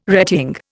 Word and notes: retying: Indian

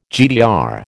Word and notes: GDR: American English

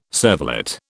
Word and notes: cervelat: British English

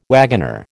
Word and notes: wagoner: American English

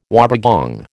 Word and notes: wobbegong: American English

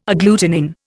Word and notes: agglutinin: Indian

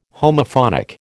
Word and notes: Homophonic: American English

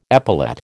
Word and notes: epaulette: American English

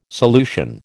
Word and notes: solution: American English

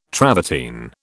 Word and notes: travertine: British English

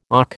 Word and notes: auk: American English

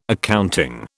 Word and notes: accounting: British English

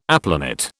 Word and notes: aplanat: British English

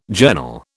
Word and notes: journal: British English

